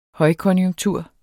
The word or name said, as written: højkonjunktur